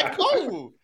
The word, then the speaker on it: Ekwonu: I see his name. It's Ekwonu.